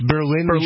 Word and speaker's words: Berlin: So, the self-driving truck, the AFP reporting from Berlin, Berlin. I messed it up. It's Berlin. Germany, and Berlin, New Hampshire. – Right, I'm so used to pronouncing the New Hampshire version of it.